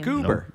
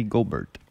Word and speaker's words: Gobert: So you don't know Gobert is the same thing? It's a French name. – Goober. – No, that's Rudy Gobert.